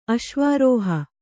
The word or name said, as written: ಅಶ್ವಾರೋಹಿ